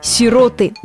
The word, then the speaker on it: сироты: Ответ однозначный с точки зрения всех словарей. «Сироты» – ударение исключительно на «о».